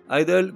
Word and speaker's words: Idles: Candy Gronaz, Idol, qu'on pourrait traduire par feignasse et qu'on prononce bizarrement comme idol, est le groupe de la résurgence punk par excellence 40 ans après.